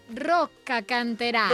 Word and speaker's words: Rocca Canterano: El poble de Rocca Canterano... Fan la festa dels cornuts. – Has de dir Rocca Canterano. – Rocca Canterano.